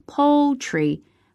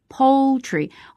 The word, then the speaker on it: poultry: 那么在这里 poultry 跟大家说一下发音，它的重读音节呢是落在第一个音节上， poultry Poultry，我们在发这个音的时候呢，难点是在这个单词当中的这个边音 L，很多中国同学直接在发音的时候就把它省略掉了，读成 poultry， poultry 就特别像是诗歌的那个单词，这里是 poultry， poultry， poultry， poultry， poultry， poultry， OK，好，那么 poultry 是什么呢？